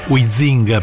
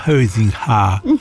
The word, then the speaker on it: Huizinga: perché ho sentito una volta la pronuncia dello storico Huizinga, per noi, per gli olandesi diventava Huizinga, cioè, pronunciato dagli olandesi è terribile, quindi diciamo che la pronuncia tedesca ci salva per tanti aspetti.